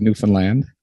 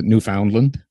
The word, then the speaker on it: Newfoundland: That's how we pronounce it, is Newfoundland. Everyone who from outside this place pronounces it Newfoundland or Newfoundland. So the simple little mnemonic we give them is understand Newfoundland. There you go. Now you have it.